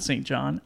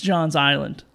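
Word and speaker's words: Johns Island: Not St. John. Am I saying that right? I'm saying that wrong. It's John's Island.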